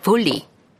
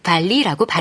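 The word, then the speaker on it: volley: 이 볼레가 영어로 들어가서 철자가 V-O-L-L-E-Y로 바뀌었는데 영국식 영어에서는 볼리, 미국식 영어에서는 발리라고 발음한다.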